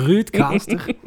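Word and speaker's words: RØDECaster: Excuseer, hoe spreek je dat? De... Eh, oei, oei. – De Roadcaster. De Ruudcaster. Ehm, Net zoals andere dingen in het leven moet je dat zijn tijd geven en met liefde behandelen.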